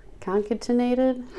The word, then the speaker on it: concatenated: And interestingly enough, the creature of the Howler has been linked to a society of lumbermen known as the concatenated? – Huh? – It's a word. I had to look up how to pronounce it.